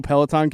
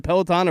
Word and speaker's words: Peloton: so Peloton came to my mind. Am I pronouncing it correctly? Is it Peloton or Peloton? No, it's Peloton.